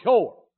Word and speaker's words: shore: Now, if you want to help me, you'll learn to shout what helps me, and that's the word shore. We spell it S-H-O-R-E, like the seashore, but we really mean it like sure, but we don't say sure from where we're from.